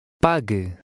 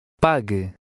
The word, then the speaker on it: Pague: Pague. Pague. – Notice that the U is silent.